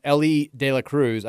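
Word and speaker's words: Elly De La Cruz: Text says, is it pronounced Ellie De La Cruz? I had no idea. It is.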